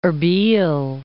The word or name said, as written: Erbal